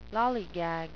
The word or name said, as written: lollygag